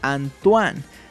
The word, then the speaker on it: Antoine: Por cierto, el nombre de mi primo Antoine se pronuncia Antoine.